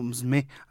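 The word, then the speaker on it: musume: De hecho, casi la U no se pronuncia, es como msme, algo así.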